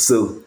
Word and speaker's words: Suh: Suh.